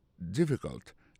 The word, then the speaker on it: difficult: Is it difficult? Difficult là, ça veut dire difficile. Lié plus comme ça, D-I-F-F-I-C-U-L-T. Difficult. N'a pas remarqué que accent tombé sur le premier syllabe là.